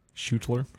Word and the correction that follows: Schulter: S-C-H-U-L-T-E-R. – Shoulder.